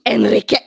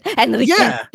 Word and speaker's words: Enriqueta: Enriqueta. It's just how you say it. Enriqueta.